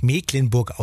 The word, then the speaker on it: Mecklenburg: So, und für alle Skeptiker, die immer noch sagen, aber Mensch, Mecklenburg, das wird doch mit CK geschrieben, da muss es auch so heißen. Gleich um 9.20 Uhr erklärt uns Björn Berg vom Kreisagrarmuseum Dorf Mecklenburg im Landkreis Nordwestmecklenburg, warum es dennoch Mecklenburg ausgesprochen wird.